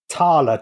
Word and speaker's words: thaler: The original pronunciation was thaler, T-H-A-L-E-R, and the T-H changed to a D. Thaler, dala.